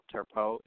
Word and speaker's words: Turpeau: And so for your listening viewers, my last name is spelled Turpeau.